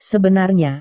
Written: sebenarnya

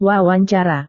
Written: wawancara

